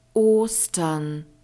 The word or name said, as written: Ostern